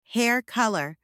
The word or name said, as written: hair colour